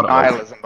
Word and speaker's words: nihilism: I thought it was nihilism, but I'm not sure. – Really? – It's pronounced nihilism, by the way.